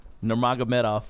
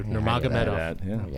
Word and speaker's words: Nurmagomedov: How do you say it? – Nurmagomedov. – There you go. Thank you. Nurmagomedov.